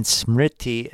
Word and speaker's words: Smriti: And the transliteration of Sanskrit, Shruti is spelled S-R-U-T-I, and Smriti is spelled S-M-R-I-T-I. And both have diacritical marks to further demonstrate the correct pronunciation. I don't claim to have perfect pronunciation of Sanskrit by any means. I think I'm fairly close on those two words, though.